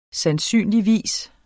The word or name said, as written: sandsynligvis